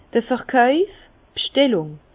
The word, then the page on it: d'r Verkauf, d' B'schtellung: Alsatian; Bas-Rhin; Herrlisheim